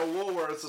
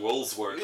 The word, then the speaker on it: Woolworths: Oh, so they were, like, at a Woolworths or something like that. And, you notice how he stopped calling it Woolsworth. Yeah. You said it right.